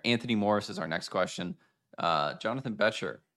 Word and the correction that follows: Becher: Becker. – The Shark. Becker. God, I cannot pronounce Jonathan Becker. Every time I try, it's like Becher.